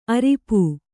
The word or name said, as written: ಅರಿಪೂ